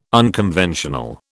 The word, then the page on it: unconventional: British English